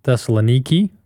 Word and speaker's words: Thessaloniki: We went to Athens, Corinth, Thessaloniki, which is how they pronounce it over there, and several other places there in Greece.